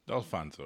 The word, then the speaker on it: Dalfonzo: Is that L silent or is it pronounced? – It's pronounced, yeah. Okay. It used to have an apostrophe in it, but my grandfather took it out. I don't know why. Okay. I never got the him about it, but the L is pronounced Dalfonzo.